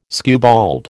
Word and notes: skewbald: American English

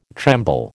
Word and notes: tremble: American English

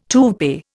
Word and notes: toupee: Indian

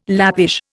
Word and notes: Lappish: Indian

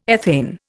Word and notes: ethane: Indian

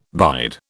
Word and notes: bide: British English

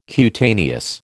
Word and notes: cutaneous: American English

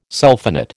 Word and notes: sulphonate: American English

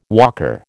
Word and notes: walker: American English

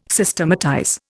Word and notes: Systematize: Indian

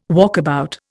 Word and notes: walkabout: Indian

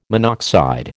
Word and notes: monoxide: American English

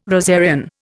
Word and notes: rosarian: Indian

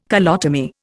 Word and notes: colotomy: Indian

